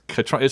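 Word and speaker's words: Caitríona: I know it's Katrina. It looks like K.I. Triona, but it's Katrina.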